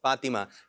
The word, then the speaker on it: فاطمہ: اس کا درست تلفظ فاطمہ, یعنی توا کے نیچے زیر ہے,